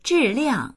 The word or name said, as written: zhi4liang4